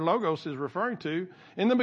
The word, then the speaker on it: logos: Now, that's some people pronounce this logos. I pronounce it logos. That may be West Texas pronunciation.